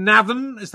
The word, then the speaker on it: Navan: Well, talking of Ireland, you come from Navan.